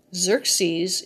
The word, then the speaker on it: Xerces: For information on what to plant to attract bumblebees to your yard, Google Xerxes Society Plant Lists. Xerxes is spelled X-E-R-C-E-S.